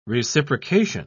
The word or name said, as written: reciprocation